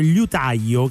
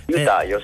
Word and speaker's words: liutaio: perché il mio dubbio sull'utaglio io pensavo liutaio, che pure è difficile da pronunciare Liutaio. – liutaglio eh, sì.